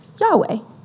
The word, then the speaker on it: YHWH: And it translates, in Hebrew, it translates YHWH, which we pronounce Yahweh.